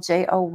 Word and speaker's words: Joye: I'm on Facebook and my last name is spelled Joye.